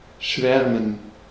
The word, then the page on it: schwärmen: /ˈʃvɛʁmən/; [ˈʃvɛɐ̯mn̩]